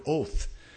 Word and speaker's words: oth: The Hebrew word for signs here is the Hebrew word oath. And like we would say oath. Of course, it doesn't mean at all what our English word oath means. And it's spelled O-T-H if you transliterate it into English.